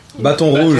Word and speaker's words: Baton Rouge: Or how do you say it? – Baton Rouge. Baton Rouge. Yeah, there we go.